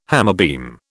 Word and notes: hammerbeam: British English

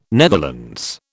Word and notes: Netherlands: British English

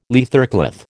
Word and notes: leathercloth: American English

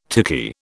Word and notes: tiki: British English

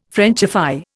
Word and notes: Frenchify: Indian

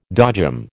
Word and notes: dodgem: American English

